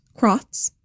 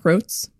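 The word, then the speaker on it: Krotz: Today's episode is produced by the wonderful Josh, whose last name is pronounced Crotz, not Croats, like I said last time.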